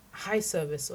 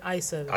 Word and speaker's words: eye service: So even if it's only high service, or is it eye service? – Eye service, yes. – You know, being Yoruba, I'm never certain of which way to pronounce it.